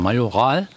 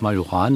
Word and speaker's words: Majoran: Und Heldrum spricht man Majoral und eigentlich heißt es Majoran natürlich.